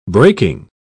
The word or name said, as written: breaking